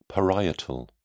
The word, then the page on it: parietal: British English